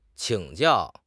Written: 请教

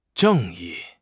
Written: 正义

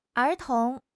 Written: er2 tong2

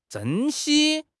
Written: 珍稀

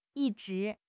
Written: yi1 zhi2

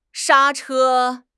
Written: 刹车

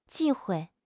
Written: 忌讳

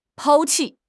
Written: 抛弃

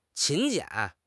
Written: 勤俭

